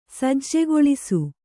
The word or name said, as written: ಸಜ್ಜೆಗೊಳಿಸು